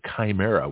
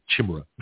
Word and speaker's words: chimera: The word chimera, which you might pass over, I pronounced it by mistake, chimera, as I'm quickly reading through.